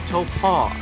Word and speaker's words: Taupo: And then, hey, Supercars was returning to New Zealand at Taupo, which now I know how to pronounce. I am now aware that that is how it is pronounced. It is pronounced Taupo.